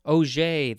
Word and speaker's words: Auger: Oh, actually, Auger. Ah, Auger, that's how it's pronounced, because it says that.